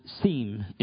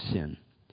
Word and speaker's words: Sin: It's actually pronounced Sim in the ancient Hebrew, and it's related to an old Egyptian city. That's the area that they came into. And but we pronounce it in our translation Sin.